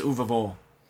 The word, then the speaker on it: Uwe Boll: When he says it, when he says his own name, he says Uwe Boll. That's how he pronounces Uber. It. But I've always pronounced it as Ubol or whatever.